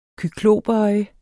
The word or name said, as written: kyklopøje